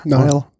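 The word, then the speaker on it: Neill: Whitley Neal. – Nile. – Nile wird das ausgesprochen? – Ich glaube, ja. – Okay, wenn du das sagst, dann Nile.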